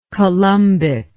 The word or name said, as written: columbic